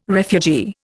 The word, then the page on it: refugee: Indian